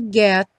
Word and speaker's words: get: When there are clouds in the sky, you'll get by. Get by. O T aí do get, típico de consoante oclusiva. Não tem som, não fala get.